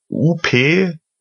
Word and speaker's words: Upspeak: Upspeak schreibt man wie UPSPEAK, also oben und sprechen, Upspeak.